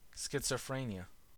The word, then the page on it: schizophrenia: American English